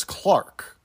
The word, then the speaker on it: clerk: that they pronounce the word clerk as Clark, like Clark Kent or Clark Griswold.